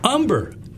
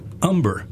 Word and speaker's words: Humber: For some reason, I want to say Humner, but I know it's Humner. Is that right? – Actually, it's Humber. – Humber.